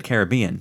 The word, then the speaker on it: Caribbean: So, by the way, in that commercial, they pronounced it Caribbean. So I'm going to go with that, okay, not Caribbean.